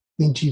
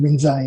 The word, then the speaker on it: Linji: Linji is pronounced Rinzai in Japanese, and he founded the branch of Zen that is now the Rinzai School of Zen in Japan.